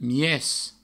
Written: mies

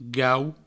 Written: gau

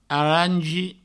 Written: arangi

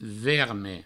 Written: verme

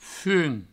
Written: fum

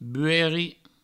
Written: buerri

